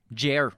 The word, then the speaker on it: Jair: Supporters of the former Brazilian president, Jair, how do you pronounce that? Jair. We're just going to call him Jair.